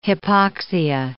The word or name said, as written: hypoxia